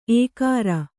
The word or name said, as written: ಏಕಾರ